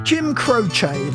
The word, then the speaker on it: Croce: Jim Croce, that's how you say it.